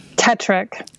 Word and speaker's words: Tetrick: Tetrick.